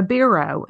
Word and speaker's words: biro: She gave him a biro. And I was like, I had to look that up. I was like, I don't know. Am I saying it right? – Yeah, no, you are.